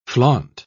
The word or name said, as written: flaunt